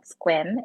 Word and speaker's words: Sequim: There's a place called Squim. It's spelled S-E-Q-U-I-M.